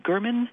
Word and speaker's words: Gurman: As many of you know, her last name is Gurman, pronounced with a hard G, like the country,